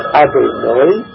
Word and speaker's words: Adonai: For example, we use the name Hashem, Yudkei Vodkei, which we pronounce Adonai, but this is never a name which we pronounce as it is written.